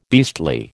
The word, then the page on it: beastly: American English